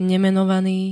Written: nemenovaný

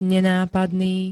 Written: nenápadný

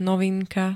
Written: novinka

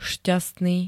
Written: šťastný

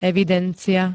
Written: evidencia

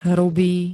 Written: HRUBÝ

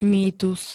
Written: mýtus

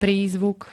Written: prízvuk

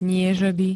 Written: niežeby

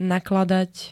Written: nakladať

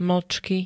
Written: mlcky

